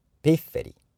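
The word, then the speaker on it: pifferi: で、アクセントの位置にまず注意ですね。後ろから 2 番目が強くなるのが英語の、イタリア語のアクセントのルールなんですが、 3 番目になってます。ピッフェリ、ピッフェロで、歌の中では複数形のピッフェリという形で出てきています。笛といっても、この言葉のピッフェロという音からもなんとなくわかりますが、小さい高い音でピッピッとなるような笛です。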